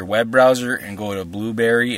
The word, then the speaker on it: Blubrry: It's not spelled like Blueberry, but if you type podcast in Blueberry, you'll find it easily. – Okay. – And you can just straight up hit it up. – Blueberry is spelled, actually, it's BLUBRRY.